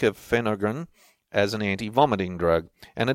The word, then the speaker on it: Phenergan: It seems they rely on something called Phenergan, P-H-E-N-E-R-G-A-N, Phenergan, and they even don't pronounce that the same way as we do. They call it Phenergan, Phenergan.